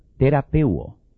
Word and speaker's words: therapeuo: En el original griego, se usa la palabra terapeuo, o sea, terapia. Se escribe T-H-E-R-A-P-E-U-O, terapia.